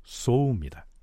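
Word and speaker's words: 소우입니다 (宗): 종은 일본 발음으로는 소우입니다.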